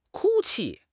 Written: 哭泣

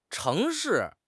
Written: cheng2 shi4

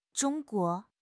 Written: Zhong1 guo2

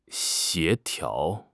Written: 协调

